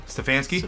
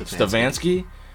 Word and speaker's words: Stefanski: Stefanski. – Stefanski.